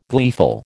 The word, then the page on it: gleeful: American English